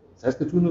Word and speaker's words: Saskatoon: Saskatoon is the pronunciation.